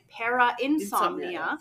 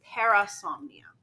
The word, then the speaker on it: Parasomnia: In our last episode, I kept calling it Para-insomnia, but it's actually Parasomnia.